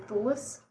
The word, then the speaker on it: Thewlis: Thewlis.